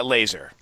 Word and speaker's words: Lazor: OC Bill Lazor, is that how you pronounce his name? – Laser. – Laser. – Yeah, Bill Laser, yeah.